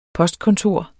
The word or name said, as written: postkontor